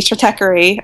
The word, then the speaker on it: Stratechery: But I think there's kind of like Ben Thompson on, let's see, Stratechery. I hope I pronounced that. – Yep, you pronounced it rightly.